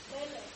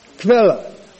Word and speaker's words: Quelle: How do you pronounce this German word for source? Quelle. Do you get it? Quelle. If we looked at it, it would be Q-U-E-L-L-E. Quelle. Something like that.